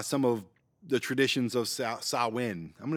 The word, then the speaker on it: Samhain: Halloween, the holiday, I mean, it has its roots in ancient Celtic festival of Samhain.